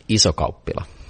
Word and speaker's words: Isokauppila: Isokauppila.